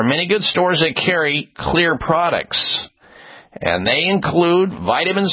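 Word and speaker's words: Xlear: And you also have big box stores, drug stores, CVS, Rite Aid, and other retailers that carry Clear, spelled with an X, X-L-E-A-R, Clear Nasal Spray products.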